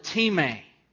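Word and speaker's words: time (τιμή): That word honor is the Greek word time. Time, it's spelt just like our word time, and it's for those who have been tested.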